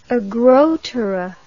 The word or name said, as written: agrotera